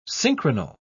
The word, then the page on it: synchronal: British English